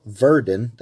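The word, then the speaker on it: Werden: When the king died, the book was lost until discovery in the Abbey of Verden. That's with a W, but the Germans pronounce W with a V.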